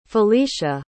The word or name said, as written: felecia